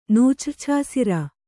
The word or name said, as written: ನೂಚ್ರ್ಛಾಸಿರ